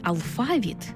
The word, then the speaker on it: алфавіт: Наголос у слові «алфавіт» на другому складі основний у нашій мові, бо збігається з грецьким «алфавітон», від якого й походить українська лексема.